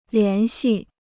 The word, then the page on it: 联系: lián xì